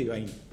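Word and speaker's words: Yvaine: Ivain, se pronuncia Ivain, porque lo sepáis, pero bueno, sí, se escribe Ivaine, que también se puede decir.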